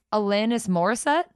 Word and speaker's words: Alanis Morissette: Alanis Morissette.